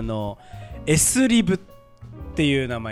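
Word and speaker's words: SLIB: で 1 個のさ頭の S だけは S って読んで、あとはリブって読みますが S リブって言います、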